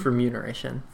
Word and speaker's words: remuneration: Isn't it remuneration? – It's remuneration. – Really? – Yeah. – It's a word I've been saying wrong for a very long time.